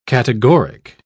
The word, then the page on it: categoric: British English